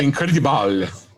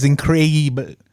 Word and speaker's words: Increíble: El Hombre Incredible. – Because the way it's spelled here, is Increíble. – Increíble. – Increíble. Thank you.